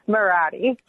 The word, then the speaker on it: Marotti: How do I say your name? – Marotti. – All right, I was close.